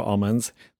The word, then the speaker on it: almond: So I'm from a smallish rural town in Northern California, and my family grows almonds, but they don't say they grow almonds. They say they grow almonds. It's like a particularly, like, Central Valley accent. You don't pronounce the L in almond.